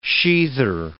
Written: sheather

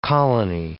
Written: colony

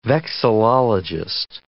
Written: vexillologist

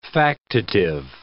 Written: factitive

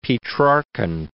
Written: Petrarchan

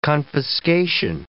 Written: confiscation

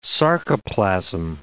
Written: sarcoplasm

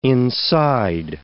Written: inside

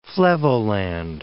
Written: Flevoland

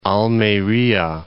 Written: Almeria

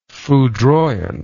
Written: foudroyant

